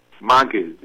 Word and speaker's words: maggid: But the word is M-A-G-G-I-D in Hebrew. How do you pronounce that? – Magid.